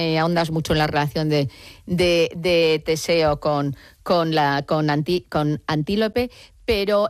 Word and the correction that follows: Antíope: me gustaría, lo he dicho mal el nombre, es Antíope.